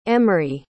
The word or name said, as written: emry